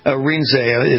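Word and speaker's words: Arinze: And I want to make sure I'm saying Francis Cardinal Rinzé. Is that correct? – That's correct. That's how he pronounces his name.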